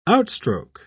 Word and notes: outstroke: British English

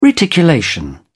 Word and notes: reticulation: British English

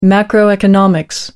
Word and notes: macroeconomics: American English

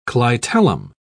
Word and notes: clitellum: British English

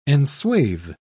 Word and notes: enswathe: British English

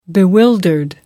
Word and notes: bewildered: British English

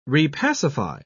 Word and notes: repacify: British English

